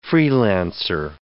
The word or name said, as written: freelancer